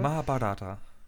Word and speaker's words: Mahabharata: Mahabharata.